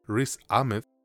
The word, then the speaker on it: Riz Ahmed: Fingernails es dirigida por Christos Nikou y protagonizada por Jesse Buckley, Riz Ahmed, ya lo dije bien, güey, eh, Rhys Ahmed, Jeremy Allen White, Jess Chef y Luke Wilson.